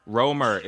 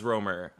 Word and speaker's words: Romer: I knew it was strange because I was like, well, they roam, so use that as like a mnemonic device and like remember their last name is pronounced Romer.